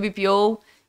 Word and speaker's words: BPO: Falando um pouquinho aí mais sobre o BPO. Enfim, um bate-papo bem legal, bem interessante. – Falei certo? – Falou, falou certo. Me cobraram essa semana. Falaram assim, não, não é BPO. Não, é BPO.